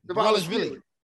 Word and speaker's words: Dvalishvili: Dvalishvili.